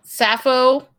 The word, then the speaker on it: Sappho: Sappho.